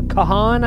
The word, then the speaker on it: Kahan: Noah uh, Kahan, I think is how you say his last name, uh, K A H A N, teamed up with Zach Bryan for something super special called Sarah's Place, and we share it right now in the show.